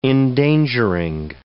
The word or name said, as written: endangering